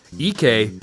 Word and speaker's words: Ike: E.K. Anibogo. – E.K.? – E.K. – How do you spell E.K.? – It's spelled I-K-E. It looks like Ike, but I looked at the pronunciations for all these.